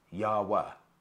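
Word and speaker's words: Jawa: Okay, the Java, no, I get that wrong. It's not Java, it's Yawa. Yawa, I keep getting the pronunciation wrong.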